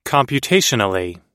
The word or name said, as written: computationally